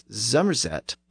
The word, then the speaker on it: Somerset: So the county of Somerset in the southwest of England is sometimes pronounced as Zomerset by locals.